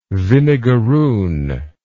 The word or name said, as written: vinegarroon